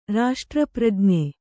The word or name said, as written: ರಾಷ್ಟ್ರಪ್ರಜ್ಞೆ